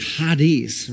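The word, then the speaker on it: hadis (χάρις): Uh, uh, it's, it sounds when you say it, when you say it, it's like hadis, right?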